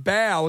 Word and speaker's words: bao: And we've just discovered Bao Buns, but they are literally pronounced Bao, and they're spelled B-A-O, Bao.